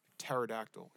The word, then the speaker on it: pterodactyl: Tell me why there's a fucking P at the beginning of that goddamn word. Why is it spelled pterodactyl, but it's pronounced pterodactyl?